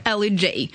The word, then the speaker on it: L.E.J.: Надо ее произносить вот так вот на английский манер. – L.E.J.